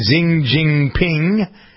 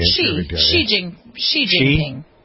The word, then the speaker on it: Xi Jinping: Number 9, Xi Jinping. I didn't pronounce that correctly. – Xi Jinping. Yeah.